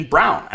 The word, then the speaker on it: Braun: How is his name pronounced Christian Brown? Someone tell that. Someone explain that to me. It's Christian Braun. I mean, come on. There's no way this is pronounced Christian Brown. Someone make that make sense. Someone. Right? It's not like, how is it pronounced Christian? It's literally pronounced Christian Brown, and I don't understand how.